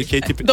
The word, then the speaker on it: Katy Perry: Katy Perry.